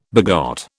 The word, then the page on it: begot: British English